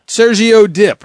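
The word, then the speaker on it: Sergio Dipp: Sergio Dip, right? – Yes, that's it.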